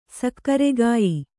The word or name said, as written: ಸಕ್ಕರೆಗಾಯಿ